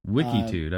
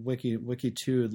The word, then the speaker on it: Wikitude: Wikitude. I've never heard of that. – Yeah. It's spelled just like, you know, Wikipedia or something. Wikitude, like latitude.